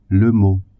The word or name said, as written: mot